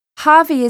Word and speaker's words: Harvey: Harvey is spelt H-A-R-V-E-Y.